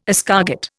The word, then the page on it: escargot: Indian